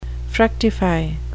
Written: fructify

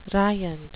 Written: riant

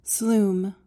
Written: sloom

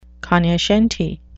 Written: cognoscenti

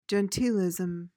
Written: genteelism

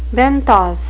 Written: benthos

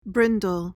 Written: brindle

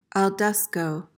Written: al desko

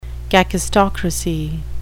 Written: kakistocracy

